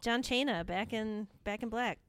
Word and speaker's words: Cena: I like John Cena. – That'll be fun to talk about, I think. – Also, I just want to be clear. We both know that his name is pronounced Cena.